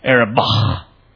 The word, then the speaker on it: Arabah: It goes down into the Araba. Or actually, I guess the Hebrew pronunciation would be the Arabah.